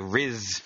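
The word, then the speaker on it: Ryse: You're pronouncing it wrong, by the way. – Rise, whatever. – Sounds like Riz.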